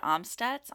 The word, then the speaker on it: Amstutz: You can find me at Sandra Amstutz on all social platforms.